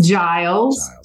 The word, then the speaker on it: Giles: Is it hard G or soft G on the Giles Giles? – It's Giles, okay.